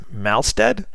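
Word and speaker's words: Mahlstedt: and I believe his last name is pronounced Malstead. It's M-A-H-L-S-T-E-D-T. Colors are by Andy, and I believe his last name is pronounced Yanchos. It's Y-A-N-C-H-U-S.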